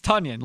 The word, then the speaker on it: Tonyan: Yeah, it's Tonyan, like Funyan, Tonyan. Yes, right.